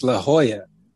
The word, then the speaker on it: La Jolla: And then La Jolla, California in 1991. He died in 91. – He died in La Jolla, California. What the fuck is La Jolla, California? – It's La Jolla.